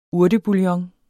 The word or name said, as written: urtebouillon